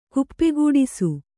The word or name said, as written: ಕುಪ್ಪೆಗೂಡಿಸು